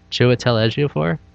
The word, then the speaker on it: Chiwetel Ejiofor: Chiwetel Ejiofor. – Ejiofor.